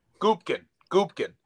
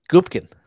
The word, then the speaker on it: Gubkin: It's Gubkin.